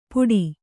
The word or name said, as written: ಪುಡಿ